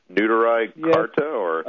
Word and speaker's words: Neturei Karta: And if you could pronounce that, the Neturei Karta? – Neturei Karta, Jews United Against Zionism.